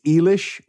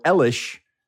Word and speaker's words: Eilish: A lot of people mispronounce her name because it's spelled E-I-L-I-S-H. And people say it's Eilish or Elish. But her name is Billie Eilish, is how you pronounce that.